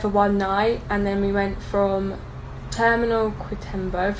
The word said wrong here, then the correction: Quitumbe: Quitumbe. – Quitumbe.